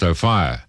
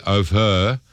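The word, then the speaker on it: Ophir: Luke, the O'Fire in Hotel in Orange is pronounced O'Fire. Some people say O'Her. So while the first is proper, the second can be acceptable.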